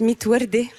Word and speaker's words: Mitwerde: Zidni et Electric Yasmine, votre album s'appelle Mid Verd et ça veut dire sans rose en arabe. – Oui, alors ça se prononce Mitwerde, pardon.